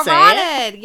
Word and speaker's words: carotid: Carotid, yes.